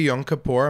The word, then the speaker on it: Yom Kippur: So, happy Yom Kippur.